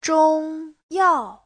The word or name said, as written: zhong1yao4